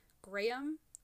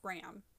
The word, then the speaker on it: Graeme: the name, you can pronounce it either Graham or Graham.